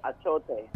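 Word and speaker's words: achiote: But is that achote? – Achote, yes.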